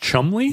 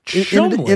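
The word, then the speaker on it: Cholmondeley: Yeah, Chalmondeley. Is it pronounced Chumley? – Yeah, they're so Chumley.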